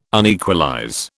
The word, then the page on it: unequalize: British English